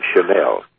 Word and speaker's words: Chanel: He wanted a different name, and I decided I wanted to keep it in the family, so my mother's maiden name is Channel. So that's what I came with, and he says, okay, but we'll pronounce it Chanel.